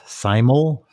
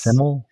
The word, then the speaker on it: Simul: I think it's pronounced Simul. – Yes. – Simul. – Simul, like simultaneous, I assume.